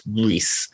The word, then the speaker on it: Gris: is apparently pronounced Gris, spelt G-R-I-S.